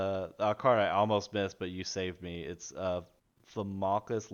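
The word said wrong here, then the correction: Velomachus: Lorehold. I don't know if I pronounced that right. – Velumachus. – That's what I said.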